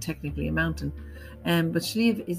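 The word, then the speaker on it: Sliabh: In Irish, there's no V in Irish language, so it's S-L-I-A-B-H. And B-H in Irish gives a V sound at the end of a word, so Slieve na Cailí.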